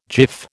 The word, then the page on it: jiff: American English